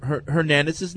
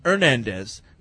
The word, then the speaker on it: Hernandez: But whereas grammar cop Eric has been on the mispronunciation of Hernandez's name, he has yet to correct any of you on the intended pronunciation. In Spanish, the H is silent, so the name should be pronounced Hernandez, not Hernandez.